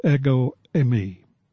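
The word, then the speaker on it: ego eimi (ἐγώ εἰμι): In Greek, it's pronounced ego eimi.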